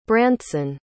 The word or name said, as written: brantson